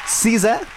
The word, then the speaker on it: SZA: Se agarró un nombre artístico muy icónico, Sisa, que de hecho ella ya explicó que se pronuncia así porque es Sisa. Su nombre se escribe S-Z-A, pero pues lo pronuncia como con una I intermedia.